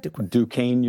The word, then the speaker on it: Duquesne: And you have an MBA degree in finance and real estate from DeQuest. How do you say that? – Duquesne University. Duquesne. – Oh, Duquesne. Yep. Duquesne. Yeah, that's French, right? – Yes.